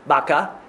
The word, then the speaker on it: bakah: It appears to me, however, that the word baca, B-A-C-A in our English, and similar in Hebrew, is related to another Hebrew word, B-A-K-A-H, baka, and it's pronounced the same way, baka.